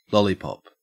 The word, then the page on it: lollipop: Australian English